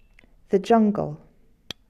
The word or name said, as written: jungle